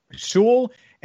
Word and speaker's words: Sewell: But I do think that the strategy of, okay, we're going to draft Penei Sewell anyway, or Sewell, God, I keep saying it wrong, Penei Sewell anyway,